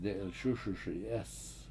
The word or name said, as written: de7ilS!S1Si7EEs